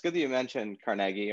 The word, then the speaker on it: Carnegie: And, you know, other than perhaps Carnegie, Andrew Carnegie, I always pronounce it that way, because that's what I heard, even though everybody says Carnegie Mellon. I always heard it was Carnegie. – It probably was Carnegie in the Scottish.